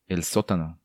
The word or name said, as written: El Sotano